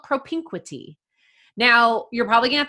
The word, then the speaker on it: propinquity: Propinquity, and I'm going to spell it for you. P-R-O-P-I-N-Q-U-I-T-Y.